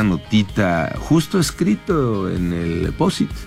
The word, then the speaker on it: Post-it: P-O, ajá, acento, ajá, S-I-T. – Post-it. – ¿Así? – Así, como suena. – Pues ahí suena, sonaría POSIT. – Sí, o POSIT. – No, POSIT.